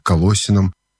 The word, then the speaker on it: Колоссянам: Кстати, я буду произносить Колоссянам, потому что это люди, которые жили в греческом городе Колоссы. А есть еще и произношение Колоссянам. Ну вот мы все-таки выберем произношение Колоссянам с ударением на втором слоге.